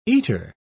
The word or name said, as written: eater